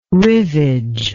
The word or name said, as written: rivage